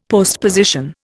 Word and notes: postposition: Indian

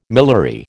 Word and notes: miliary: American English

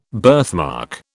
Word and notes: birthmark: British English

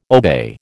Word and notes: obey: American English

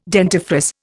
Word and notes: dentifrice: Indian

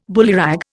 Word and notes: bullyrag: Indian

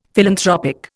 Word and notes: philanthropic: Indian

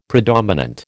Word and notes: predominant: American English

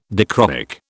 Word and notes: dichroic: British English

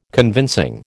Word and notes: convincing: American English

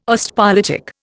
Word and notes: Ostpolitik: Indian

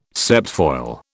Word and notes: septfoil: British English

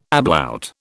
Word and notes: ablaut: British English

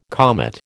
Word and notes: comet: American English

